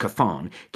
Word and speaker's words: Chthon: Would you pronounce that demon Chthon? Chthon? – That's how I've been saying it in my head, yeah.